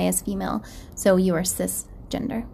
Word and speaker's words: cisgender: No, it's actually cis with a C. So oh. It's spelled C-I-S, gender.